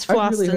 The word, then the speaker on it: Flowstone: Is that actually pronounced like that? – It's Flowstone, really, yeah.